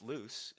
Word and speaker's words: Luce: It's Luce, L-U-C-E, but it's pronounced Luce.